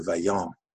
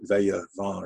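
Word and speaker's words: Taillevent: It's Les 110 de Vaillant. And it should have been, I called it Veille Vent or something like that. I added an extra syllable.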